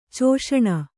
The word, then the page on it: ಚೋಷಣ: Kannada